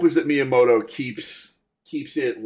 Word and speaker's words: Miyamoto: Miyamoto, yes.